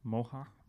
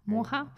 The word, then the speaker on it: Moher: Also die meisten kennen ja die Clips auf Moher. – Moha. – Moha.